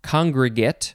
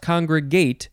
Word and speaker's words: congregate: Second form, this one is pronounced congregate, not congregate, congregate, spelled the same way.